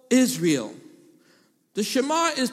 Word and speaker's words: Shema: If you're taking notes, it's spelled as a Hebrew word, S-H-E-M-A, Shema.